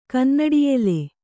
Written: ಕನ್ನಡಿಯೆಲೆ

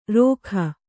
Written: ರೊಖ

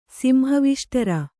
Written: ಸಿಂಹವಿಷ್ಟರ

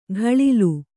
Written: ಘಳಿಲು

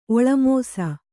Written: ಒಳಮೋಸ

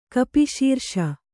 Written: ಕಪಿಶೀರ್ಷ

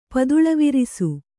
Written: ಪದುಳವಿರಿಸು

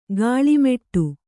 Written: ಕೀಳ್ಮಾಡು

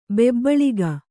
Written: ಬೊಬ್ಬುಳಿಕೆ

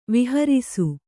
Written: ವಿಹಾರಿಸು

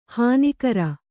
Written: ಹಾನಿಕರ

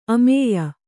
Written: ಅಮೇಯ

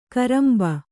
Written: ಕರಂಬ

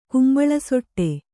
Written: ಕುಂಬಳಸೊಟ್ಟೆ

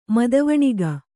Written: ಮದವಣಿಗ

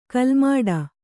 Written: ಕಾಲುಮೆಟ್ಟು

